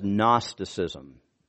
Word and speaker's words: Gnosticism: It was known as Gnosticism. It starts with a G, but the G is silent.